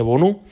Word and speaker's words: Bono: Y ese 1 ha sido un gol que, bueno, eh, la liga se lo atribuye al portero marroquí del Sevilla, Bonú, escrito Bono, como el cantante, como el exministro, pero vamos, en teoría se pronuncia Bonú.